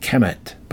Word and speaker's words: Kemet: As a matter of fact, the ancient Egyptian name for Egypt was KMT, so it's pronounced Kemet by some people.